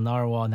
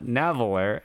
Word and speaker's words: narwhal: Yeah, it's a narwhal, Naveler. – Narwhal. – Yes. – It's not Naveler, as much as I want to say it. It's narwhal.